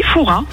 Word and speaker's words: Fourras: Eh bien écoutez, on dit Fourras. On ne prononce pas le S.